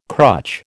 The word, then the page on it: crutch: American English